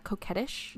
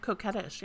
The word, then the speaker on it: coquettish: Is that how you pronounce that? Coquettish? – Yep, coquettish, yeah.